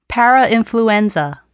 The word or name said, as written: parainfluenza